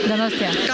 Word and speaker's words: Donostia: Donostia. – Donostia.